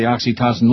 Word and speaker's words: oxytocin: Just like oxytocin.